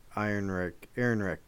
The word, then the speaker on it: Ehrenreich: And we did say his name wrong, the guy playing Han Solo, and I apologize. It's Alden Ehrenreich. Still can't say his last name, but his name is Alden. – Ehrenreich.